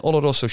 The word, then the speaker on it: Oloroso: En hier ga ik waarschijnlijk eh, de, de, de, de fout in met Palo Cortado en Oloroso sherryvaten. – Ja. – Heb ik het goed uitgesproken? Heel goed.